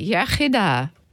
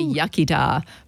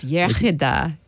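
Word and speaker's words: iechyd da: You say yachida, good health. – Yachida. – Yeah, the English say yachida, but it's yachida, good health.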